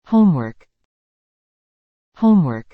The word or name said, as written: homework